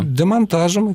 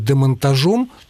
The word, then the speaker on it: демонтажем: Да-да, разница в ударении. Если мы с вами ставим ударение на окончании, склоняя слово «монтаж», у нас получается «монтажом», и под ударением по правилу пишется «о». Без ударения, когда мы произносим «демонтаж» в творительном падеже, «демонтажем», без ударения оказывается «е». Если вы будете говорить «демонтажом», тогда будет «о». Но так, по-моему, говорить нельзя.